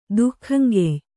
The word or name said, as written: ದುಃಖಂಗೆಯ್